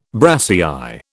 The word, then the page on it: brassière: British English